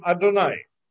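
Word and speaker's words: Адонай: А мы его произносим не так, как оно читается, как произносим Адунай, да, тот, кто знает. Барух это Ашем, то есть называем это Аднут, называем его Аднут, не произносим там.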